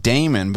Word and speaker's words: daemon: The people that created the internet, they chose the term demons, and it's pronounced daemon, but that's where demons come from.